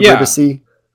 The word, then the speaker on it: Ribisi: But then Beck married Marissa, and I don't know how to pronounce his name, Ribisi, Giovanni Ribisi's brother. – Wait, what? – Giovanni Ribisi, Ribisi.